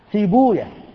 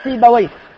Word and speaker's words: سيبويه: وجاء عندنا كما حكى عالمان جليلان استخدام زعم بمعنى قال، أول هذين في بوية إمام أهل اللغة، ولعلكم تستغربون نطقه هكذا، ينطقه المحدثون سيبويا، وهو مشهور عند الناس سيبويه، وإلا المحدثون يقولون سيبويا وحمدويا وراهويا ونفقويا وخالويا وهكذا إلى غير ذلك. لماذا؟ لأنهم سمعوه سماعا، وهذه أسماء أعجمية، فلما كانت أعجمية عدنا فيها إلى لغة أهلها، نسمع كيف ينطقونها فننطق كما نطقوا، وهم ينطقونها سيبويا وحمدوية وخالوية ونفقوية وراهوية، فلم يتعدوا السماع.